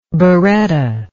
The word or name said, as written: berretta